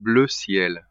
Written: bleu ciel

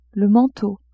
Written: manteau

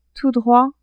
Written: tout droit

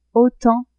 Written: autant

